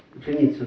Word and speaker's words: ученица: Ученица ударение на ученица, значит, это ученица все-таки, да, ученица. Да.